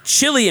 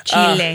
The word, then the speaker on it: Chile: close to what Chile emits in a year. So, Chile. – Chile, you gotta say it right.